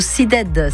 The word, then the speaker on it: SYDED: Vous pouvez vous adresser au CIDED. Ça s'écrit S-Y-D-E-D.